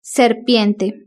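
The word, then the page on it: serpiente: Spanish (Spain)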